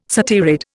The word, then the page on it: satyrid: Indian